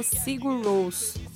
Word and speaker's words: Sigur Rós: Se escreve Sigur Rós, mas se fala Sigur Rós.